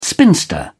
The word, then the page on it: spinster: British English